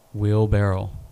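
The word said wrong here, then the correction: wheelbarrow: It's barrow. – It's not barrel? – Barrow.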